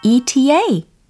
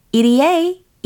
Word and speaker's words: ETA: ETA, EDA, ETA, EDA. 네, 편한 발음으로 선택하시면 되고, 이게 무슨 뜻이었죠?